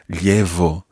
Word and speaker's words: llevo: Now, in some parts of Latin America in particular, you will hear that pronounced as a V, llevo. But in Spain, you would pronounce it more like a llevo.